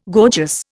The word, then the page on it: gorgeous: Indian